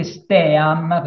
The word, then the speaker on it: STEAM: Leggo volontariamente STEAM perché si legge STEAM, proprio scritto STEAM,